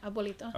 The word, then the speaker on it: abuelito: gentleman in there, this old abuelito, is that how you say it? – Yeah, abuelito.